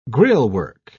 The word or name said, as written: grillwork